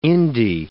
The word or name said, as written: indie